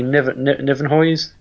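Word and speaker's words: Nieuwenhuys: Some people say it's Nivenhuis, but it's apparently Nivenhuis.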